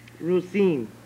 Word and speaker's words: Rosine: Rosine. – How do you spell it? – R-O-S. – Uh-huh. – I-N-E.